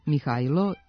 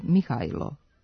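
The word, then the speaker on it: Mihailo: U srpskom jeziku postoje obe varijante imena, sa i i sa j, Mihajlo i Mihajlo, kao i prezimena Mihajlović koje neki izgovaraju i Mihajlović, da bi se razlikovali od Mihajlovića sa j. Međutim, srpski knez zvao se Mihajlo, sa i.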